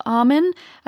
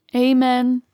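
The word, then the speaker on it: Amun: So a ghost story tells the tale of a high priest of Amen. I think you can pronounce it Amen, Amen. But it's A-M-U-N, so I'm going to say Amen.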